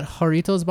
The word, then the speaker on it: Jarritos: Can I see that Jarritos bottle of yours for a sec? – Great pronunciation.